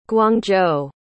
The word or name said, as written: guangzhou